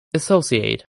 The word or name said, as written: associate